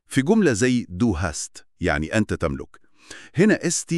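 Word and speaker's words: hast: ليه بننطقها هاست بالسين مش هشت؟ – سؤال مهم جداً، وده جوهر القاعدة. القاعدة دي مرتبطة ببداية المقطع الصوتي وبس. – يعني ليها شرط مكاني. – تماماً، لما التركيبة دي تيجي في الوسط أو في الآخر، الإس بترجع لصوتها الأصلي الطبيعي اللي هو سا. – بالظبط، فكلمة هاست بتفضل سا واضحة، والفرق ده حاسم.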